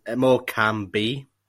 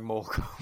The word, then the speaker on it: Morecambe: Yeah, when you say it does sound like Morcom. Morcom. that, that's, that's how they pronounce it down there.